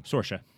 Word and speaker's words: Saoirse: There was like, I think Sayoris did it, or I'm saying her wrong. – Saoirse. – Is that how it's pronounced? – Saoirse Ronan, really?